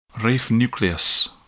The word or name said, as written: raphe nucleus